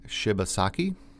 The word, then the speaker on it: Shibasaki: The other one is Watercolor by Shibasaki, and he's it's a Japanese gentleman.